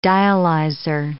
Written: dialyzer

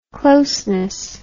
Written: closeness

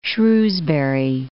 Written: Shrewsbury

